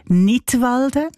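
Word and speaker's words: Knidwalden: Nidwalden, ausgesprochen wie der Kanton Nidwalden, aber geschrieben mit einem K vorne dran.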